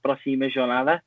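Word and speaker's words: Próxima Jornada: And yeah, it was a good pronunciation. So it's Proxima Jornada.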